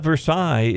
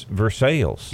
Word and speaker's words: Versailles: In Kentucky, it's not Versailles, it's Versailles. – Versailles. Mm. – They say Versailles, Kentucky. And if you say Versailles, they know you ain't from around there.